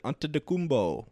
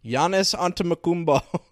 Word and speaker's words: Antetokounmpo: Antetokounmpo. Wait, no, that's a... Is that his name? Giannis Antetokounmpo. Fucking, how do you say his last name? – I think that's right. – Giannis Antetokounmpo.